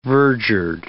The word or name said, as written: verdured